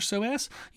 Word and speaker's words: psoas: Now, for the core, we do want to pay attention to a muscle called the psoas, which is P-S-O-A-S. So it starts with a P, even though it's pronounced psoas. So there's no P there in the sound of it.